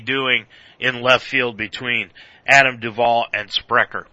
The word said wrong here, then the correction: Schebler: It's Schebler.